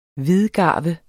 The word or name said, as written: hvidgarve